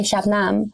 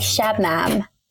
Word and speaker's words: Shabnam: My parents would say Shabnam, like Nam. But when English people say, they're like Shabnam, you know?